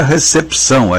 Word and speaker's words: recepção: A gente escreve recepção e pronuncia recepção. A gente lê o que está escrito.